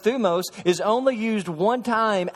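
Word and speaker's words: thumos (θυμός): The first Greek word is thumos. It looks like thymos. It's pronounced thumos.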